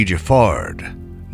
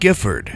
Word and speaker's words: Giffard: The first Hugo, or Hugo de Giffard, not Gifford, as it'll later come to be pronounced,